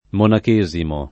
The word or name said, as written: monachesimo